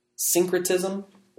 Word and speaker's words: syncretism: Syncretism? – The first syllable is sync, which means, you know, to combine. Okay, syncretism, you're syncretizing two things.